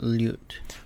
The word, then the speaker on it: ljut: On je ljut. – So we have ljut. That's the LJ sound. The same word as in ljubav. Same pronunciation.